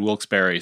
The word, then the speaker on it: Wilkes-Barre: And a lot of people pronounce it Wilkes-Barre or Wilkes-Barre, but my old friend from there said Wilkes-Barre, so that's what I went with.